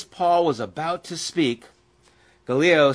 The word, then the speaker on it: Gallio: This guy's name, I keep wanting to pronounce it Galileo, but it's not. It's Gallio.